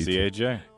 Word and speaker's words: CAJ: Artist is by the name of C-A-J. – Okay. – So I don't know if you pronounce that Cage, Cage, or just say C-A-J. – C-A-J.